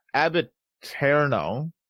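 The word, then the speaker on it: ab aeterno: Abiterno, that's how you say it.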